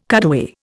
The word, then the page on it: cutaway: Indian